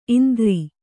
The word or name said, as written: ಇಂದರ